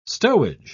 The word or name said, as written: stowage